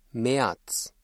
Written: März

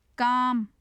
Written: kaam

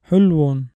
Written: حُلْوٌ

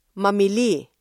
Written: mamili

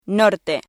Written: norte

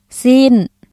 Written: ຊີ້ນ

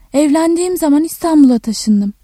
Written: zaman

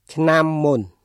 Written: ឆ្នាំមុន

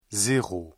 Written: zéro

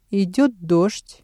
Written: идёт дождь

